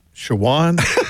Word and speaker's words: Chowan: And then Chowan, and I'm like, Shawan? – He goes, I've always pronounced it wrong, too, then.